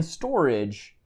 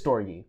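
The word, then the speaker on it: storge: It's the word storage there on when you spell it out in English. In Greek or Latin? – Latin. – It's storgy. – No. It is Greek. It's Greek. It's Greek. Yeah. – In Greek, it's storgy.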